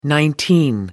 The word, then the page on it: nineteen: American English